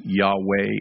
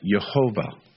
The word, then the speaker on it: Yahweh: I have seen some of these texts, and at various places in the text, the name and the vowel structure sets it up for a two-syllable name, like Yahweh or Yahweh. In some places, it sets it up as a three-syllable pronunciation name, like Jehovah, like what you've suggested.